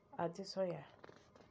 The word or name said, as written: Adesanya